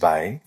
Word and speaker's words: Weil: She was her surname is spelled W-E-I-L, but pronounced Weil.